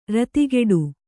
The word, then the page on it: ರತಿಗೆಡು: ratigeḍu